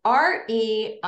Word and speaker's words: Reims: They lived in the same town, by the way, and it's called Reims. It's hard to say, but it's Reims, Reims. And they pronounce it a little bit differently.